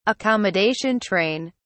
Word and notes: accommodation train: British English

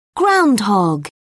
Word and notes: groundhog: British English

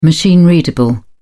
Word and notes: machine readable: British English

